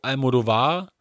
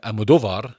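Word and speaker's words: Almodóvar: Ähm, es gibt zum Beispiel bei Pedro Almodovar, äh, Almodovar, so spricht man ihn aus, äh, gibt es eine Grundthese, die sich in jedem Werk finden lässt.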